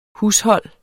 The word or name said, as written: hushold